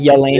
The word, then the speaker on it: Yelena: And at the end of this scene, you see my love life, Yelena, at Natasha's grave. No, it's Oh, it's Yelena? – Yeah.